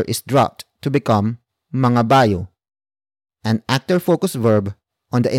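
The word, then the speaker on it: mangabayo: And that is mga bayo.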